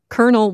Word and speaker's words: colonel: Colonel was spelled C-O-L-O-N-E-L, but pronounced Colonel.